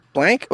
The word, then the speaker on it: Blanc: Blank? Wait, no, blank? It's French, so you don't pronounce a C.